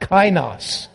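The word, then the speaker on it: kainos (καινός): I had the wrong word or the wrong pronunciation the first service. Kainos, neos neos, two different words.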